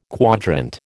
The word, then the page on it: quadrant: American English